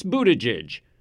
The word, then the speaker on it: Buttigieg: It's Buttigieg, as in boot edge edge.